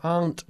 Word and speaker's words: aren't: Tienes que pronunciar más fuerte la T al final de aren't, ¿ya? Those aren't watches, they're chairs.